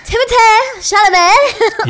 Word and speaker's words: Timothée Chalamet: Timothy Chalamet. – Timothée. – I thought you were going to give Chalamet? – It a bit more than that. – Oh, sorry. Timothée Chalamet.